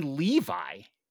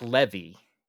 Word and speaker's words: Levi: Zachary Levi is the proper pronunciation of that dude's name. I have been saying Zach Levy.